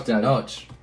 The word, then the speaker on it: Tenoch: N-O-C. Oh, Tenoch. – Yeah, thank you.